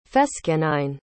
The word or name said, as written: fescennine